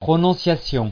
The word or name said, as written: prononciation